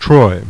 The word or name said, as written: Troy